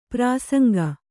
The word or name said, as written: ಪ್ರಸಂಗಿ